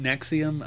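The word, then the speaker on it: NXIVM: I think they actually pronounce it Nexium. I mean, it's a weird spelling, and they got...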